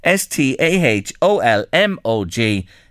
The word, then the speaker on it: Staholmog: and, and, that lady or, gentleman there, whoever it is, said to us, uh, Staholmog with the hall as well, pronouncing the H O L in it as well. So you're right, Louise. There, you see, local knowledge in the Royal County. That's uh, what uh, makes all the difference. So today on Late Lunch, our misplaced pronunciation: S T A H O L M O G is Staholmog to get it 100% right.